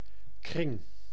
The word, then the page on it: orbite: [ɔʁ.bit]; France